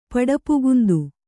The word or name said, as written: ಪಡಪುಗುಂದು